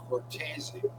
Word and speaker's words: Cortese: The correct pronunciation is Cortese.